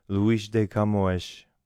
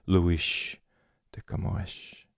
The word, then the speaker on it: Luís de Camões: Portuguese is not a language that hisses with S's. It hishes with eshes. There's a lot of sh sound. Luís de Camões, great elegant and eloquent poet.